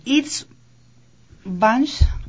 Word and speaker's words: Istvansch: Está con nosotros entonces Itz Bansch, así se pronuncia, pronunciación Itz Bansch.